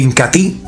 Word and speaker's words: Incatea: Y otro producto líder de las diarras Amazon, sin lugar a dudas, es el Incati. Se escribe Incatea, pero se pronuncia Incati.